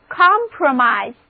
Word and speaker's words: compromise: 那么如果我们在 promise 之前加上表示一起的 com com 前缀，单词 compromise 最后的 i 字母发音变作 i 的本字母音， read after me 就是 compromise，字面上的意思就是大家都允诺了，一个大家都同意的东西势必做了折中，所以 compromise 意思就是折中、妥协。